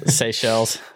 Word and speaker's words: Seychelles: Seychelles. – Seychelles. Sorry, I always do that. I have to remind myself to pronounce something differently. It's not phonetic.